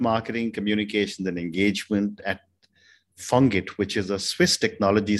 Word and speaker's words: FONGIT: So at FONGIT, it's pronounced FONGIT because of the French language, of course.